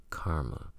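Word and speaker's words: CCRMA: The reporter seemed intrigued by the possibility that the participants had formed a group in search of its acronym Center for Computer Research in Music, purportedly pronounced Karma.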